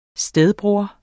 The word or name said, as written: stedbror